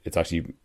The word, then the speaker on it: Bezzecchi: The guy whose name I hadn't heard of and couldn't pronounce correctly last time, Marco Bezzecchi, is how you pronounce it.